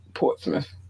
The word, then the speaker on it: Portsmouth: And she, she's from Portsmouth. – Portsmouth. – That's a myth? – Smith. That's how it's pronounced.